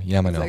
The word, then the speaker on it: Iameno: It really spells as it pronounced. – Yeah, I-A-M-E-N-O, Yamano.